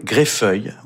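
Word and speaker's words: Greffulhe: La pavane de Gabriel Fauré, il l'a composée pour la comtesse Greffule, prononcée Greffeuil, on ne prononce pas Greffule, qui était une beauté d'une élégance inouïe à l'époque de Marcel Proust.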